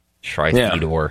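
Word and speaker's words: Shri Thanedar: Two reps, both from Michigan, Haley Stevens, and I think it's pronounced Shri Thanidar. Thanidar? Perhaps. – Cool name. – Yeah, it sounds like a Marvel villain, actually. – Shri Thanidar. – Oh my God, it's Shri Thanidar.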